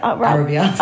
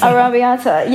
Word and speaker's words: Arrabbiata: How do you say it? – Arrabbiata. – Arrabbiata. Yeah.